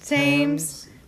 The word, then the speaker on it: Thames: The Thames. – Thames.